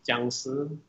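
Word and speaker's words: Jiangxi (僵尸): It's the Chinese word for hopping vampire, so it's pronounced Jiangxi, but yeah.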